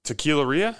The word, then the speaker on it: tequileria: tequileria. Am I saying that right? – Yeah.